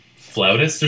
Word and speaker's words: flautist: Or am I just, what was it, like, the same flautist or something?